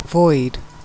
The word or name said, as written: void